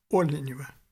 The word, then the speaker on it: Оленева: Ирина Павловна Оленева, в ее письме такое ударение на «о», Оленева, Петербург.